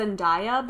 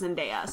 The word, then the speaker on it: Zendaya: I always thought it was Zendaya, but every time I see her on TV, she's... – It's Zendaya. – She's announced as Zendaya, so...